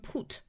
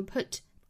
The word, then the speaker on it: put: 这里呢大家要注意一个 put 的发音，就是 put, 大家不要把它发成了紧张音 put, 啊，应该是一个 put。哦，这个音呢在汉语里面是没有对应的发音的，所以大家需要去找准口型，是哦 put,